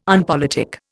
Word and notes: unpolitic: Indian

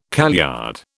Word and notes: kaleyard: British English

